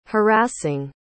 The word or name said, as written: harassing